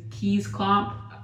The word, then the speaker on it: Kees Klomp: His name is Kees Klomp. That's the Americanized way to say his name. – You know how it's spelled? – K-E-E-S. And then last name is K-L-O-M-P. – So it could be pronounced Key Klomp.